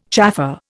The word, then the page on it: chaffer: Indian